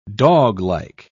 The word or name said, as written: doglike